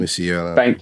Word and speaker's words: Musiala: Is it Musiala? Someone may need to clean up my pronunciation. – Musiala. – Thank you.